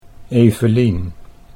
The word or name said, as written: Evelien